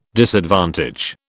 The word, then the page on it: disadvantage: British English